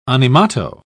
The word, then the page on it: animato: British English